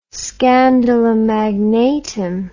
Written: scandalum magnatum

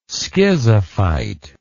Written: schizophyte

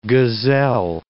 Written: gazelle

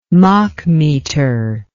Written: machmeter

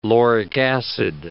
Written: lauric acid

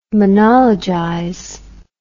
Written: monologize

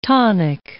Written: tonic

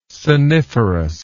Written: soniferous

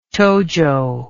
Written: tojo

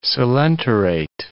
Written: coelenterate

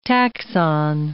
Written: taxon

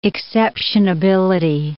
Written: exceptionability

